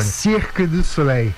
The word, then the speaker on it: Cirque du Soleil: Ô, Cazu, ensina ele a falar Cirque du Soleil aí, que acho que ele falou errado. Por favor, como é que... qual que é a pronúncia correta em francês, você que esteve lá durante muito tempo?